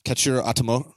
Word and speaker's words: Katsuhiro Otomo: Katsuhiro Otomo. Mm-hmm. Akira. – Katsuhiro Otomo? – Yeah.